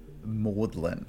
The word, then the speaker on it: Magdalene: No, I mean, you may have heard of the specific colleges there that are called Maudlin. – I haven't. – Okay, well, they're spelt Magdalene. – But they said Maudlin.